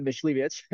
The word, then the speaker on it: Mysliwiec: My name's Jamon Mysliwiec.